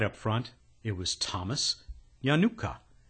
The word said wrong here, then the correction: Yanukta: Yanukta. They go to the trouble of giving us this phonetically, and then I ignore it. Thomas Yanukta.